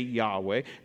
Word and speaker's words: Yahweh: So they developed a tradition, which is when they came to the word YHWH, which we say Yahweh, when they came to that name, instead of pronouncing that name, they would say Adonai, which in English is Lord.